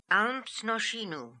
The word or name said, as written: Almznoshinoo